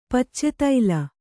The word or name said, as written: ಪಿಚುತೂಲ